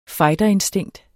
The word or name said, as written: fighterinstinkt